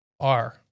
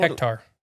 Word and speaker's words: hectare: Hectare. – Yeah, I know. – Hectare. – Yeah. – Like reptar.